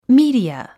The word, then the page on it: media: míːdiə; ミィディア